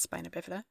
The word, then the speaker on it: spina bifida: But anyway, the main issue with Miracle in Lane 2 is the fact that the boy who has, spina bifida. My dad's gonna say that I pronounced that wrong, and I don't care. – No, you had it. It's spina bifida.